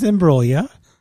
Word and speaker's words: Imbruglia: It is Imbruglia. The G is silent. – It's what? – Imbruglia. Natalie Imbruglia. – That's what I said. – No, you had a G in there, a hard G. No, it is a silent G.